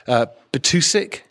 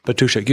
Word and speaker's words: Batůšek: So Robert Batůšek, am I saying that correctly? – Almost, Robert Batůšek. – Batůšek.